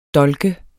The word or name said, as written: dolk